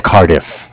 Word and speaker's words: Cardiff: I'm from Bank of Cardiff. It's spelled C-A-R as in car, D-I-F-F as in different. Like Cardiff, Wales.